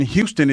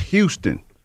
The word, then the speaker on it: Houston: It was called Houston. – Many cities have Houston. – It's spelled Houston though. – So if I saw it for the first time, I would say Houston as well.